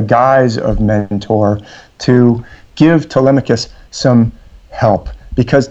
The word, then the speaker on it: Telemachus: Young Telemachus, when I was little, I thought it was Telemachos because that's how it's spelled. But usually people say Telemachus.